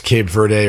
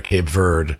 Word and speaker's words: Cape Verde: I'm seeing, by the way, it's pronounced both ways. It's pronounced Cape Verde or Cape Verde, but the Portuguese pronunciation is Cabo Verde.